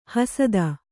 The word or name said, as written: ಹೊಸ್ತು